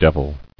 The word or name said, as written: Deviled